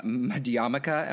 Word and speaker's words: Madhyamaka: which is Nagarjuna and Madhyamaka, am I pronouncing that right? – Yep, you are. – Madhyamaka.